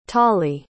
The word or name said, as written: tollie